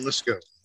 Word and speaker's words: Muskogee: And Muskogee? Am I? Am I? Where are you? I in am Okie from Muskogee. – I love that. I had to make sure I said it correctly.